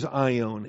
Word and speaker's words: aion: The noun for age is aion, A-I-O-N, alpha, iota, omega, nu. A-I is the long O, omega, aion.